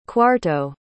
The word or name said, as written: cuarto